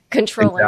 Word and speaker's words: comptroller: Controller. – Exactly. Yeah. – It's spelled comptroller, but pronounced controller.